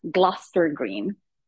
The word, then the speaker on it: Gloucester Green: It is that stop you wanted, but it's pronounced Gloucester Green.